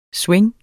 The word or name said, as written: swing